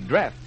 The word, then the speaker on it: Dreft: Dreft. I'll spell it D-R-E-F-T, Dreft.